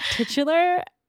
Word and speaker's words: titular: I was like, do you pronounce that titular? And he was like, yes. And I was like, okay, because I think Sam might have been laughing at like the word tit before in it. But like, I wanted to make sure it wasn't just me that was doing it wrong. And he was like, no, I think that's how you pronounce it.